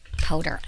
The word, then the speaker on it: Potter: I'm so excited to welcome my guests, Cam and Karen Lee Porter. – It's Potter. – Okay, why is it? I said Porter. Potter. I knew that.